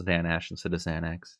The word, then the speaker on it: Xanesh: It's this Dark Inquisitor Xanash, I guess. Like it ends with S-H. I don't know how you'd like actually pronounce it. It's good enough. X-A-N-E-S-H, Dark Inquisitor Yanash.